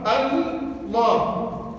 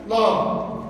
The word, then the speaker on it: الله: أضغمتا، أضغمت اللام في اللام، لما رفعت الهمزة أضغمت اللام في اللام، فصار في النطق عندك لامين، الأولى ساكنة، الثانية مفتوحة، الله، الله. اللفظ الجلالة هذا يرقق بعد الكسر، يرقق بعد الكسر، تقول قل هو الله أحد الله الصمد، أحد الله، فترقق بعد الكسر تقول أحد الله، بعد الفتح والضم تفخم تقول الله، لام الله، اللام مرققة بعد الكسر، الله بعد الفتح أو الضم.